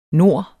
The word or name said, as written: nord